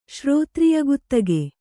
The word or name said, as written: ಶ್ರೋತ್ರಿಯಗುತ್ತಗೆ